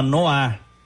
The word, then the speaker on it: noir: Quem quiser acompanhar, é, se chama Noar. Viu? Noar? Tipo, é. N-O-A? Se escreve Noir.